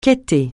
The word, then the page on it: quêter: francais